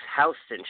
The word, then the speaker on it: Houston: It's coming to Sunshine Cinemas in New York City on Friday on East Houston Street. I know you can't say Houston, even though it's spelled like Houston.